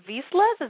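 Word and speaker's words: Vizslas: Her husband, Aaron, her young son, and two unruly Vislas, is that how you say that? – That is.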